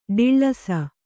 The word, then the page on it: ಡಿಳ್ಳೈಸು: Kannada